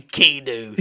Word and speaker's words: Keanu: Keanu. Keanu. – That's how you pronounce it.